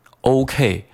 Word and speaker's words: OK: 那首先这里面 OK 你有没有读对，很多同学都会把它读成 OK OK，那这是我们一个非常流行的普遍的错误。 OK，那其实这个字母 O 它的发音就跟刚才我讲到的这个 wow 是一样的，你也需要把嘴给撅起来变成 oh，也就是你的落脚点，你这个音在结尾的时候你应该发的是一个 oh 这个音才可以哈。